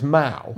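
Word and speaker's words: mow: But it's pronounced Mao. – Mao. – Like the guy with the jacket.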